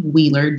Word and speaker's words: Wheeler: That's LeTesha Wheeler, WHEELER.